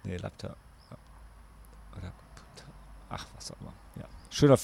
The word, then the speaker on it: Laputa: Das Ist doch Lapta und nicht Laputa, weil Laputa ja.